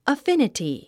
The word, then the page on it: affinity: əfínəti; アフィニティ